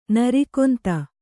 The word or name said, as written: ನಿರ್ಗಂಧ